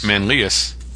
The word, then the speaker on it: Manlius: Scott Barrett from, let me scroll down, Manlius, New York. – Okay. – No pronunciation guy. Manlius. Manlius? – That sounds about right. – Manlius? – Manlius. – Manlius. Well, that's easier to say.